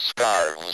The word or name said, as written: scarves